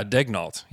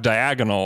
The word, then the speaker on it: Daigneault: Is that even how you say it? – Yeah, Daigneault. Yeah, you're right. – Daigneault. Okay. Yeah. All right. What I try to do is just kind of mumble the second syllable and just kind of. – Well, the Thunder broadcast doesn't even know how to say it. They call him Mark Diagonal all the time.